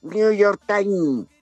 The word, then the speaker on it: New York Times: ¿Cómo, se, cómo se pronuncia con corrección? ¿Cómo es? – New York Times.